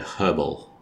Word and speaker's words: herbal: Do you pronounce the H in the word herbal? – No. Herbal.